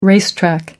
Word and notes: racetrack: American English

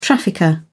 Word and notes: trafficker: British English

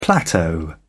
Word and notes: plateau: British English

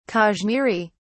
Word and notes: kashmiri: British English

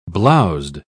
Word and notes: blowzed: British English

